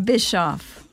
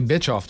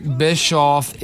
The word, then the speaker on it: Bischoff: I hear people say bitch off, too.